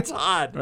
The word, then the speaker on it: Todd: See, it, like I say, you know, it's one of the few benefits of having the name Todd. My name also rhymes with odd.